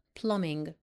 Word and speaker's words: Plumbing: نلاحظ ان البي silent لا تنطق. Plumbing.